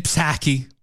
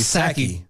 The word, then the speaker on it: Psaki: Jen Psaki. – I think that P is silent.